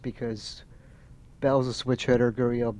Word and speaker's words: Gurriel: How do you say his Gurdjieff? Gurriel.